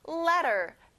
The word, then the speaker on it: letter: 그 다음은 letter. 편지 또는 글자란 뜻이에요. 우리가 이렇게 보고 싶은 사람한테 letter를 받으면 마음이 굉장히 따뜻해져요. letter. T 발음은 안 나요.